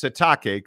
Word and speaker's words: Sitake: He wants it pronounced Sitake, Kalani Sitake.